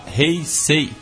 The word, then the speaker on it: Heisei: E ela lembrou que a era atual que estamos é a Heisei. Mas ela falou que o E é fechado. Então seria como Heise. E é isso.